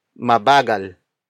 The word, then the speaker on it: mabagal: In this context, nang is used to link the action to an adjective or a verb to an adverb, which is mabagal. Mabagal. Mabagal. Mabagal is spelled as M-A-B-A-G-A-L.